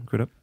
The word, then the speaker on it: Crudup: Is that how you say his name? Crudup? Yeah, Billy Crudup and Secession stars Matthew McFadden and Kieran Culkin were also participating.